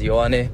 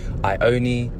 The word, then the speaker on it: Ioane: My name is Ioane, spelt I-O-A-N-E. A lot of people have pronounced my name incorrectly in various different ways. Some people have called me Ioni, others have called me Ioane. Some people have even called me Iwan.